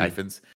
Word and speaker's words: Ifans: I want to give a shout out to Reese Ifans, I think is how you say his name, right? Mm-hmm. Ifans, who was just fucking awesome in this.